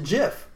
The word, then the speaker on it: GIF: But the guy who created the GIF pronounces it GIF.